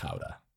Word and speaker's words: Gouda: But if you go to Holland and you want this Dutch cheese, it's pronounced Gouda.